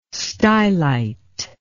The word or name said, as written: stylite